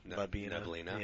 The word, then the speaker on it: Neblina: But it was the Pinot Noir, the La Nebula. – I don't know, it's Labino. Neblina. – Yeah, Neblina. It's like the Nirvana song.